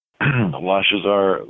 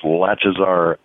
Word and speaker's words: Lashazar: Lashazar. Let me get it. It's like latch. So it's Lashazar Filipov.